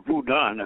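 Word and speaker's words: vodun: And so I read all those books and became fairly up on voodoo, or voodun as it's properly pronounced.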